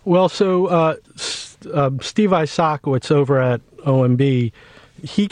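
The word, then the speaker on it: Isakowitz: I know there are some key players in this whole story, and I might butcher their names, so just correct me if I do. Steve Isaacowitz? – Yeah. – Okay. Isaacowitz. Okay.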